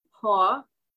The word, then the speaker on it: po: And so the five spirits are the po, or the spirit, it's P-O, but it's pronounced po.